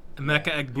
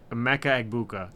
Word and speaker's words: Emeka Egbuka: Egbuka? – Emeka Egbuka. – You got the first try. – Emeka Egbuka.